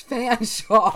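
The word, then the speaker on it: Featherstonehaugh: Featherstone Hall, which is Fanshawe.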